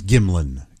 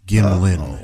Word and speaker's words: Gimlin: It's Gimlin. Gimlin. – Gimlin. – And Chad lets me know if I'm going to be a pretender Bigfoot believer to at least pronounce Gimlin.